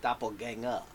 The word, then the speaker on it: Doppelganger: Next up is Doppelganger. – That's how it's pronounced. – That's just spelled. Weird. – That's your language, guy. – Not my language. – Yeah. No. Germanic. Germanic in origin. – Yeah.